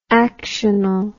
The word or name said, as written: actional